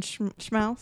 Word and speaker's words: Schmaus: S-C-H-M-A-U-S. Uh-huh. Schmaus. – Yeah. – Yeah. You really got to overdo the schmaus. – Like a schmaus. – Yeah. Like schmutzig.